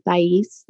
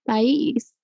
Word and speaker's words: Thais: I'm Thais, like phonetically Thais, so THIGH and YEESE, Thais.